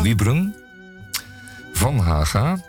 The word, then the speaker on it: Wiebren van Haga: Eh, ik denk aan VVD Tweede Kamerlid Wiebren van Haga. Zeg ik het goed? – Ja. – Wiebren van Haga.